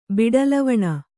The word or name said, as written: ಬಿಡುಲವಣ